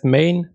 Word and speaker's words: Gmane: Er sagt, the G is silent, das heißt main, mine oder mine. – Main. Na gut. Ich finde Gmane gut. – Gmane.